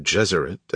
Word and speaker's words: Gesserit: It is no accident that the pronunciations of Gesserit and Jesuit are similar, as he envisioned his maternal aunts and the Bene Gesserit of Doon as female Jesuits.